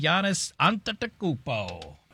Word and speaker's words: Giannis Antetokounmpo: Well, welcome to Miami, Giannis Antetokounmpo.